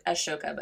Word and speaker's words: Ahsoka: It's not Ahsoka, Ashoka, but it's hard to pronounce. – Ashoka.